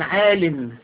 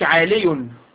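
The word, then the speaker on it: عالٍ: لا تقول عال، تقول هذا بناء عال، ما تقولش عالي.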